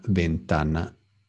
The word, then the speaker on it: ventana: Here, a lot of us say ventana, but in Spanish, the V is pronounced the same as a B, B. La ventana, not la V, no, not V, but B. Ventana, la ventana, la ventana.